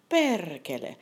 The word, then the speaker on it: perkele: So let's start with the Finnish basic swear word, perkele. P-E-R-K-E-L-E. Perkele. It doesn't sound so scary if I say it like that. Perkele. But let me say perkele. Better, right? Okay, then we have saatana. That's like Satan, or S-A-A-T-A-N-A, saatana.